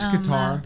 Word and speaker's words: Qatar: Is it pronounced Qatar? – You are. – I don't know if it was Cooter or Qatar. – It's Qatar.